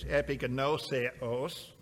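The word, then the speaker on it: epignoseos (ἐπιγνώσεως): The word knowledge in the Greek word is epignoseos, is how you pronounce that.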